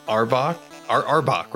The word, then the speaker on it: Arbok: I'm going to use a twin spell meta magic and burn a sorcery point to do Firebolt on both Arbok, right? Am I pronouncing that right? – Yes.